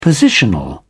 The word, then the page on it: positional: British English